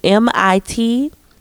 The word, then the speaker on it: Mitski: I can't pronounce Miss Mikisiki volunteer. You know, Miss Mikisi. Forgive me. Mitski volunteer.